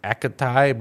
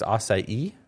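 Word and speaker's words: açaí: Everyone goes acai, but it's acai.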